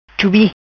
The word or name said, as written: Chubi